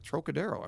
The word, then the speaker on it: Trocadero: It's pronounced the Trocadero? – The Trocadero. – Uh-huh. It's not the Trocadero. – Trocadero, I believe. – Oh, wow. I stand corrected.